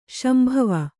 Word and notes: ಶಾಂಭವಿ: Kannada

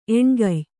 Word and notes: ಎಣ್ಗಯ್: eṇgay; Kannada

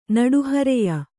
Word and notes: ನಡುಹರೆಯ: Kannada

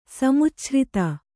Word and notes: ಸಮುಚ್ಛ್ರಿತ: kannada

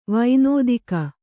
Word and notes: ವೈನೋದಿಕ: vainōdika

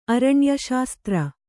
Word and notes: ಆರುಣ್ಯ: kannada

